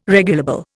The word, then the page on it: regulable: Indian